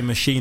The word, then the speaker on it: Moschino: So yeah, I'm glad you pronounced that right. – Yeah, Moschino, yeah. – A lot of people say Moschino, don't they? Yeah. I think it sounds better though.